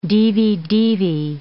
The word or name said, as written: divi-divi